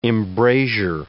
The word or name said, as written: embrasure